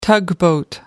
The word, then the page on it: tugboat: British English